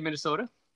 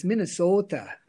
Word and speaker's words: Minnesota: So just so everybody knows, geographically, you're in Minnesota, in the great state of Minnesota. – You didn't quite say it right. It's Minnesota.